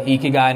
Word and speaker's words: Ikigai: So that's called Ikigai and it's spelled I-K-I-G-A-I, I believe.